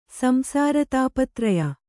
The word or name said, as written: ಸಂಸಾರತಾಪತ್ರಯ